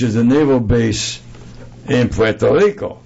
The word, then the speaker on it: Puerto Rico: It's Puerto, it's Spanish, Puerto Rico.